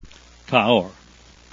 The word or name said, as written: cahors